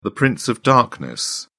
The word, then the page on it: prince of darkness: British English